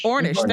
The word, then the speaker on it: Ornish: My question for you, the Amish, I hope I'm pronouncing that right, Amish or Amish? – Ornish, thank you.